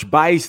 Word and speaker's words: Buice: That is my name, Marsh Buice. The U is silent. I don't know how they got Vice out of B U I C E. looks like Buse.